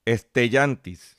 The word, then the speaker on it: Stellantis: la nueva compañía se va a llamar Stellantis. Estellantis Se escribe S-T-E-L-L-A-N-T-I-S.